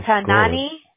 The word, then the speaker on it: Tanan: Is it Tanani? – Oh, here you go. – Something like that. – Yeah. – Tanane? Or how do you pronounce it? – Tanan.